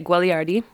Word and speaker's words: Guagliardi: It's Italian, so you say it Guagliardi. You could Guagliardi? – Yeah. – Guagliardi. – The second G is silent.